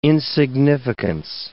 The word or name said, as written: insignificance